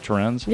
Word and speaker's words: Torrens: is that how you say it? Torrens? – Yeah.